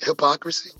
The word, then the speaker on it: hypocrisy: And, and, and and one of the key words for the reverse reverse side is deceit, and another one powerful word hypocrisy. Hip, no, I can't pronounce it properly, but you say it right, hypocrisy.